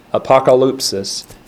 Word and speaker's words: apokalypsis: So apocalypsis, I'm having to put the stress on a different part, but you spell it A-P-O-K-A-L-Y-P-S-I-S, apocalypsis, and it's Greek word 602, and it's the noun form of apocalypto. A-P-O-K-A-L-Y-P-S-I-S, apocalypsis.